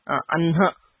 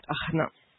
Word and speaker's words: अह्न: Okay, so but the pronunciation here is Anha, okay, and not Ahna.